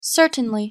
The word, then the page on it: certainly: English